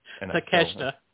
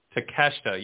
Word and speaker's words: Takeshita: Takeshita. – Takeshita, yes. Because you don't want to pronounce it phonetically. It's not good.